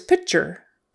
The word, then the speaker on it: picture: Um, but typically that T in the middle is going to sound like a CH. And URE is going to sound like ER. Picture, picture, picture, picture, with the accent on the first syllable.